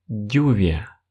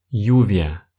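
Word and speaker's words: lluvia: Например, слово «дождь» в испанском языке будет звучать как «дювия», «дювия». Чаще всего я могу услышать от русскоговорящих вариант «ювия», как если бы в начале стояло просто и краткое. Вас поймут, но это не будет истинно испанским произношением.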